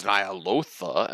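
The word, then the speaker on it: Ny'alotha: Yeah, they said it, like, several times during these initial quests, and they're like, it's Nyalotha. And I'm like, ugh, this is, like, some literally, like, Ural shit, where, like, we were saying it wrong, but, like, they just had to say it a bunch of times so that we know it's wrong now.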